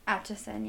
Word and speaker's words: Atchison: Do you think I said that right? Atchison? – Atchison, yeah. – Okay.